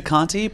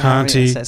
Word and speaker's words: Conte: I say Conti, but you can say Conti, so who runs Patreon.